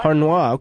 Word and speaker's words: Harnois: Is it Michelle Harnois? Harnois? – Harnois. – Harnois. Okay, we've got the French pronunciation.